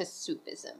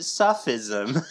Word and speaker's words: Sufism: Yes, because it's like a more Western and kind of modern approach to Sufism. – Yeah. – In general, which I actually didn't know a lot about. – Neither did I. In fact, last episode, I pronounced it Suffism.